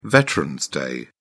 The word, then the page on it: veterans day: British English